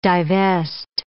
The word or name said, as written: divest